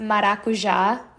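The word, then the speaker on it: maracujá: Mine, my number one is going to be the maracuja, which I'm pretty sure it's pronounced maracuja.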